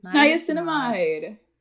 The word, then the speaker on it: niacinamide: Niacinamide.